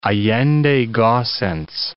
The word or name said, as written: Allende Gossens